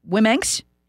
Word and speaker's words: Womenx: Okay, texter writes, if USA Womenx, is that how you say it? W-O-M-E-N-X, Womenx? Oh, yes, Womenx.